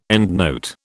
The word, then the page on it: endnote: British English